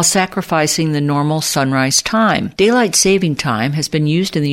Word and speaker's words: daylight saving time: For years, I always mispronounced the time change as daylight savings time. Now, there's no S in saving. It is daylight saving time.